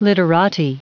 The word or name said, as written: literati